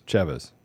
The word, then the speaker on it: Chavez: Anthony Chavez, did I say that right? – Chavez.